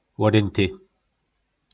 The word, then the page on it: lodynty: po nasimu